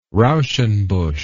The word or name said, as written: rauschenbusch